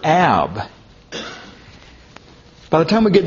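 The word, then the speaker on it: Ab (אב): It would be pronounced something like Ab.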